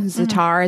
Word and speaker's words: Zaatar: A-U, so like O, Zatar, Z-A-A-T-A-R. Zatar is a spice.